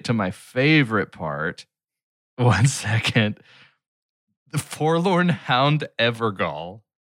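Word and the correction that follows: Evergaol: Jail. Ever jail. – Jail? G-A-I-L. Sorry, G-A-O-L. I mispronounced it.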